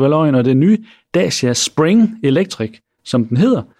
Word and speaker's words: Dacia: Og apropos børnecontainer, så har rumænske Dacia jo, eller Dacia, sådan der udtales det vist,